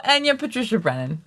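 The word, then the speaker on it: Eithne Pádraigín Ní Bhraonáin: Which I guess is pronounced Enya Patricia Brennan. – So Enya Patricia Brennan.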